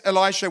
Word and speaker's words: Elisha: Elisha was spelled with SH, Elisha.